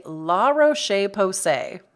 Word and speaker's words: La Roche-Posay: So when it comes to sunscreen though, my fave is the La Roche-Posay.